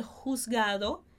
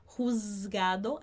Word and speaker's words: juzgado: En el juzgado, primeira coisinha aí que essa palavra el juzgado, atenção à pronúncia, a gente não vai pronunciar esse Z como se fosse um Z, a gente não fala juzgado, a gente fala juzgado, como se fosse um S, significa tribunal.